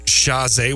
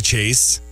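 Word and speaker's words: Chasez: Wait, JC Chazé? Wasn't his name JC Chase? – No, it's Chazé. – It's Chazé, really? – His name is JC Chazé. – Oh my God. – Z is silent.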